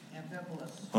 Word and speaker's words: Amphipolis: Amphipolis.